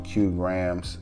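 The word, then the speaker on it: Kiu Grams: When I first heard the joint, I actually found Q Grams. Q Grams, it's not spelled with the actual Q, it's spelled K-I-U, but it's pronounced Q, Q Grams.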